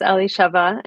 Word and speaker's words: Elisheva: It's Elisheva, and I go by Ellie as well.